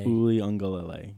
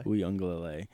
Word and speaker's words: Uiagalelei: Duke Uiagalelei, how do you say it? – Uiagalelei. – There we go. – Uiagalelei.